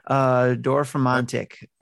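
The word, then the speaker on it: Dorfromantik: Dorfromantic.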